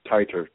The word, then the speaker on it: titer: but also I had a specific question about the TETRA test. Is that how that's pronounced? – Titer. – Titer test.